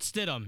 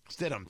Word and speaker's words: Stidham: Uh, they they traded for is it Stidham from from the Patriots? Jared Stidham, yeah. Stidham, thank you. I, I never knew how to pronounce the name.